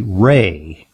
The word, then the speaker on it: Rea: This week, unfortunately, we mourn the passing of a giant in the field of integrative and complementary medicine, Dr. William J. Ray. That's spelled R-E-A.